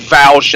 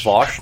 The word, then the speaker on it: Vaush: And you'll hear these stupid Westerners, like fucking Vaush and some random YouTube talking head communist, talking about how great China is. – And you go to China... – It's pronounced Vaush, not Vaush.